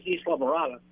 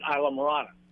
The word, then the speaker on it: Isla Morada: It's funny as anything, but it's called the Spanish name is Isla Morada, but in English we pronounce it Isla Morada.